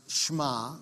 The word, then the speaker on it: Shema: So you see the word up there, Shema, but it's actually pronounced Shema. Say that with me, Shema.